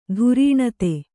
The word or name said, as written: ದಾರುಣತೆ